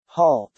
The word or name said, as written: halt